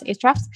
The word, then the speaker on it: Ahrefs: So I would definitely, and if you don't know, Ahrefs is actually, it's spelled Ahrefs. So it looks like a refs, but it's actually pronounced Ahrefs, but they're also very expensive.